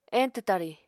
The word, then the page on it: eñtw tari: Apatani